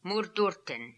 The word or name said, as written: Moordoorten